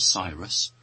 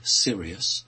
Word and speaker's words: Sirius: If we think of the constellation of planets and stars, which as a British person I pronounce as Sirius, but an American person would say Sirius, one part of that constellation has a very, very strong male energy.